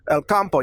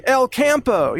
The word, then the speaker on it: El Campo: It just means the country in Spanish, but El Campo, you know, but Americans say El Campo, you know, it kind of butchers it, you know.